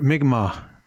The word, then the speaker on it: Mi'kmaq: Mi'kmaq.